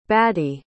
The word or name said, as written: badi